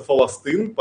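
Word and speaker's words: фаластын (فلسطين): Слово «Палестина» у них тоже нет, у них это называется «фаластын», поэтому я их называю «фалестинцами».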